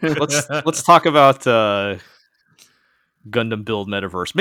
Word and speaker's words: Gundam: You're making a slight pronunciation issue there. It's Gundam Build Metaverse.